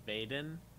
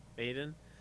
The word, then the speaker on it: Veden: So my guess as to spell it was V-E-Y-D-A-N. – Okay. It is V-E-D-E-N, which is why Elliot went for Vaden. – Okay. – But it's pronounced Vaden. – Okay. – Vaden.